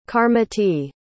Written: karmiti

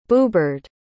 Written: boobird

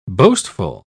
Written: boastful

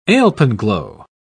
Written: alpenglow

